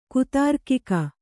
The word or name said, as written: ಕುತಾರ್ಕಿಕ